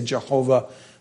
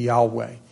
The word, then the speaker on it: Yahweh: People used to say Jehovah. It's really probably a more accurate pronunciation would be Yahweh.